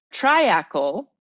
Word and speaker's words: triacle: Treacle for the UK comes from the French triacle, which I, is how I'm going to pronounce it because it's T R I A C L E, which is rooted in the Latin word Oh, and here we go.